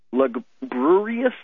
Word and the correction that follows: lugubrious: I can't even say it. – Lugubrious. – I had the emphasis on the wrong syllable.